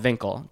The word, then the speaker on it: Winkel: In Amsterdam, it's called Winkel, W-I-N-K-E-L, pronounced like a V.